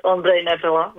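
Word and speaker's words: Ondrej Nepela: Yes, so, and very good pronunciation. So it is Ondrej Nepala.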